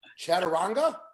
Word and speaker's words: Chaturanga: And the international yoga instructor and author of The Perfect, and if I say this right, Chaturanga? – Yes, you did.